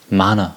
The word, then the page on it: mana: American English